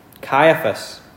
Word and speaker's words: Caiaphas: Caiaphas, that's how you say it, Caiaphas the high priest, and the word of God came to John, the son of Zacharias, in the wilderness.